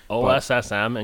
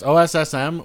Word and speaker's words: OSSM: OSSM, in case they're listening. – Yeah, it's OSSM, which just to me sounds like Awesome Comics, which is like awesome.